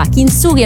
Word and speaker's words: Kintsugi: Mentre, che differenza c'è tra Kintsugi e Kintsugi? Che una è sbagliata e una è giusta. Kintsugi è la pronuncia giusta del nuovo album.